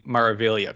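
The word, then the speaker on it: Meraviglia: Oh, thank you. That'd be the MSC Maravilla class. – Said like a pro.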